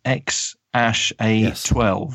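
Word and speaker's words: X Æ A-12: and the child is called XAA12, pronounced X-Ash-A12. Yes.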